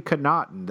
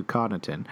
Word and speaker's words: Connaughton: And shooters or quasi-shooters like Kyle Korver, Markieff Morris, Pat Connaughton, or Connaughton, I've heard people pronounce it. – Interesting. – I think it's supposedly Connaughton, though. I would have obviously defaulted to Connaughton.